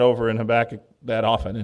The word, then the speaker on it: Habakkuk: Please turn with me over to, I pronounce it Habakkuk. You may say Habakkuk. I learned the wrong way when I was 4 and I haven't changed.